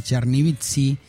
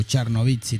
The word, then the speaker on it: Chernivtsi: Y en el 2001 viaja a Chernivtsi, que es la ciudad natal de los abuelos, o Chernivtsi también se pronuncia.